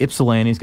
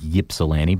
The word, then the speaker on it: Ypsilanti: And Ypsilanti's got a little tricky spelling. It's spelled with a Y. It looks like Ypsilanti, but it's Ypsilanti.